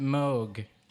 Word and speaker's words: Moog: And you should pronounce it Moog.